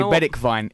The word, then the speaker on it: Bergwijn: Well, I was going to say Bedekvine is the actual Dutch pronunciation.